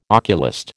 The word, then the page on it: oculist: American English